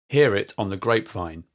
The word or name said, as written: hear it on the grapevine